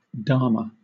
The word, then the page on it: dharma: British English